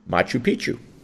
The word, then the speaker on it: Machu Picchu: you know that beautiful place in Peru called, Machu Picchu? – I don't know if I say it right, Machu Picchu? – Machu Picchu.